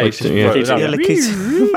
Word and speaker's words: Lakitu: How do you say that? – Yeah, Lakitu.